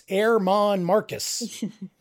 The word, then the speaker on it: Germán Márquez: I think you pronounced that name wrong, though. I've heard on the Rockies radio, it's Germán Márquez.